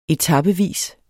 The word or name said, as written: etapevis